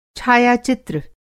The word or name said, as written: छायाचित्र